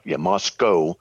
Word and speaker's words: Moscow: So, and then, Moscow is how they pronounce it, is north of me about 3 or 4 hours.